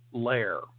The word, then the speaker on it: Ler: and then there's Lair. That's how they pronounced it. L E R. It's short for something else, but Lair as in Curly and Moe.